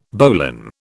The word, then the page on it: bowline: British English